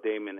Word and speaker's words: Daymond: So uh, my, my name is spelled Daymond as in Raymond with a D.